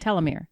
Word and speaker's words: telomere: So we won't talk about the telomeres. Is that how you say it? Telomere. Telomere. Yeah.